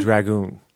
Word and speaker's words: Dragoon: Dragoon. – That's How do you spell that? – Add an O to dragon.